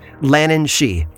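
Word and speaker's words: Lhiannan Shee: It's spelled differently but pronounced the same, Lannan she, although this creature is far more dangerous than her Irish counterpart.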